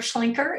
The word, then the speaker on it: Schlinker: Yeah, and that's my name, Barb Schlinker.